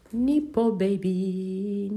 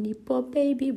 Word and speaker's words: nepo baby: Bunu yazayım bakayım nasıl telaffuz ediyor. Turenk. Nipo baby, Nipo baby.